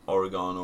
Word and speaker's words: oregano: They'd have sprinkled some oregano or fucking grass toppings. – To our American cousins. I believe you pronounce that oregano.